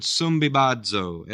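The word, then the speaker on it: sumbibazo (συμβιβάζω): That's that word sumbibazo, S-U-M-B-I-B-A-Z,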